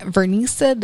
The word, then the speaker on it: Vernisa: You can check me out on Instagram at Vernisa. That's V as in Victor, ERNISA.